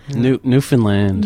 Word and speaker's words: Newfoundland: There's a special way of saying it, isn't it? – Yeah. Newfoundland.